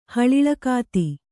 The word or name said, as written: ಹಳಿ್ಳಕಾತಿ